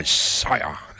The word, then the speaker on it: Sia: FM 100.3, that was a great song by Sia. That's how you say it.